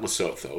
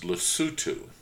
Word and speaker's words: Lesotho: Not Lesotho, it's pronounced Lesotho.